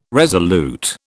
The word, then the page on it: resolute: British English